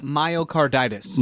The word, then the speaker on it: myocarditis: It's about from the American Heart Association, and they published a study confirming that mRNA vaccines may contribute to myocarditis. Am I saying that right? I can't say that. – Pretty close, by the way. – Close. – Myocarditis.